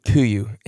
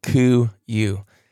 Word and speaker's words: KUIU: but it was a great podcast with Kuyu, and that's spelled K-U-I-U. And some people were saying, if I can't pronounce it, I want nothing to do with it. I know that was kind of a joke, but that's how you say it, Kuyu.